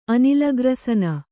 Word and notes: ಅನಿಲಗ್ರಸನ: Kannada

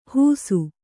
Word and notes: ಹೂಸು: hūsu

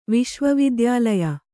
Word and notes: ವಿಶ್ವವಿದ್ಯಾಲಯ: Kannada

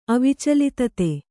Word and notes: ಅವಿಚಲಿತತೆ: avicalitate; kannada

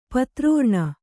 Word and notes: ಪಿತೃಋಣ: pitř řṇa

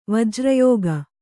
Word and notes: ವಜ್ರಯೋಗ: vajra yōta

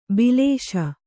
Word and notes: ಬಾಲಿಶ: bāliśa

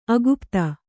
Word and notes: ಅಕುಪಿತೆ: akupite

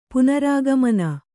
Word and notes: ಪುನರಾಗಮನ: Kannada

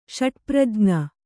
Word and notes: ಷಟ್ಪ್ರಜ್ಞ: ṣaṭprajña

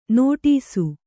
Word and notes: ನೋಡಿಸು: nōḍisu

